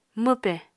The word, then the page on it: mwpe: Apatani